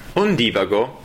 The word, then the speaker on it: Ondivago: Benvenuti ad Un Divago. Un Divago, con l'accento sulla I,